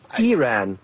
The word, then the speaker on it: Iran: It's Iran, like Israel, like Iraq.